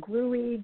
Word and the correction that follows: Grohe: a German company, Grohe.